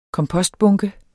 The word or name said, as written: kompostbunke